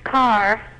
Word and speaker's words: Karr: Could you pronounce the last name for me? – It's Carr. – K-A-R-R. – Like an automobile? – Yeah.